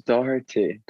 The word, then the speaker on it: Doherty: Well, like now I just say either like how you said it, or I say Doherty, because it's pronounced Doherty, Doherty. But it's just too hard for people.